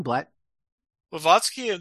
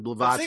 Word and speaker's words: Blavatsky: Blavatsky, there's Blavatsky. I want to say it correctly, Blavatsky.